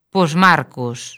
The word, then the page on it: Posmarcos: lingua galega